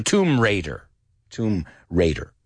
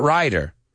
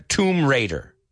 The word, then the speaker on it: Tomb Raider: Not rider, raider. No Tomb Raider, Tomb Raider. All right? You can ask that one today at your office. Que no pronuncies esta palabra bien. Tomb, como tumba. No, piénsalo. Quita la ba.